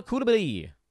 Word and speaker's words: Coulibaly: Fourth name on the list, Bilal Coulibaly. Worked very hard to make sure that we get that French pronunciation right.